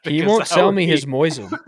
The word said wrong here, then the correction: Mosin: Who the fuck pronounces Mosin like Moisen? – Okay.